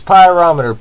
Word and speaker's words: pyrometer: I think I'm saying that right. Yeah, it's pyrometer. P-Y-R-O-M-E-T-E-R.